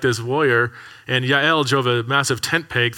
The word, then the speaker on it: Yael: It's pronounced Yael.